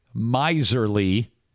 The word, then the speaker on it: miser: But it's pronounced with a long I. A long I sound. Rhymes with visor. And of course, the word miserly means to act like a miser.